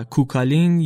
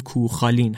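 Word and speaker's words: Cú Chulainn: از افسانه های شناخته شده تر این فرهنگ ماجراهای فین یاقی و کوکالین، یا اگر بخوایم با تلفظ صحیح تر بگیم کوخالین هستن.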